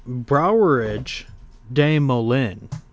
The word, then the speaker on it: Brouwerij de Molen: So, that's kind of like a nearby stationery for, Brouwerij de Molen is how you pronounce it.